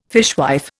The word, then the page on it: fishwife: Indian